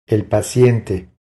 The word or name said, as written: El paciente